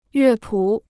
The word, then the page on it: 乐谱: yuè pǔ